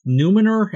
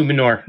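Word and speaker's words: Númenor: The forging of the rings, the rise of the Dark Lord Saranon, yes, I can talk, the epic tale of Numenor, am I saying that right? – Yeah, no. Numenor. – Numenor, yeah. Thank you, and the last alliance of elves and men.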